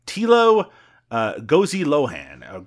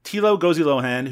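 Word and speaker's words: Thilo Gosejohann: So I have a pronunciation here that says his name is Tilo Gozi-Lohan. Gozi-Lohan? I said it a little faster that time. Tilo Gozi-Lohan, who, of course, not only directed Operation Dance Sensation from 2003, he also co-directed Captain Berlin vs.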